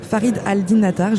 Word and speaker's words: Farid al-Din Attar: Attention, je vais tenter de bien prononcer le nom de cet auteur persan, Farid Al-Din Attar. Je l'ai bien prononcé. – Très bien, parfait.